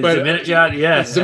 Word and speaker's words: Zibanejad: I mean, I can't say his name correctly, but Zibanejad, I think that's how it's called. Yeah. Zibanejad. Yes. – Zibanejad. I mean, yeah.